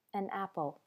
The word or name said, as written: an apple